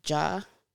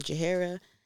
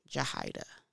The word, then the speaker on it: Jahaira: That is pronounced Jahida. When you see it spelled, call me Jahira. We might fight. No, I kid. It's not pronounced Jahira. It's not pronounced Jahida, Jahara, none of the above. It's pronounced Jahida.